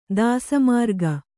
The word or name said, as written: ದಾಸಮಾರ್ಗ